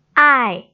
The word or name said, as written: ai4